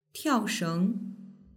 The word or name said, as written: tiaosheng